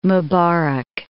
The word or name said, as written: Mubarak